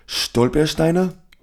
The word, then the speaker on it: Stolpersteine: If you're walking around Berlin, for example, there's these things called, I think I'm saying it wrong because it's hard to say, Stolpersteine? – No, that sounds about right.